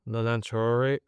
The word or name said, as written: Nolan Traore